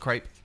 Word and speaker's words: crepe: Or creep. – Not everybody knows what a crepe is. No, it's pronounced crepe. You're a creep.